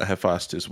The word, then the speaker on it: Hephaestus: How do you say that, bro? – I'm probably struggling. Like Hephaestus. – Yeah, Hephaestus. Well, it's either way.